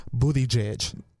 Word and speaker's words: Buttigieg: Buttigieg. – Buttigieg. – Yes. It's that's how he pronounces it.